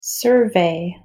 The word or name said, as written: survey